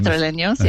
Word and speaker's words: Madrileños: I was reading one thing, Moira, that the usual escape for Madrileños, is that how you say it? – People from Madrileños, yeah.